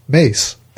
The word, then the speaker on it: Myss: Miss, M-Y-S-S, is pronounced like Mace.